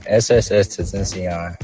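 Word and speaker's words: XXXTentacion: Like, how do you say that guy's name? Is it XX10? I don't even know how you say his name. – Yeah, SSS Tentacion.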